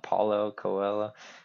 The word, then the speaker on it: Paulo Coelho: Kind of a dark book, but it has a really good message, in classic Paul Coelho, I'm probably pronouncing that wrong, Paulo Coelho form, really good message.